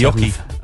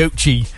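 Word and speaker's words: oche: The hockey.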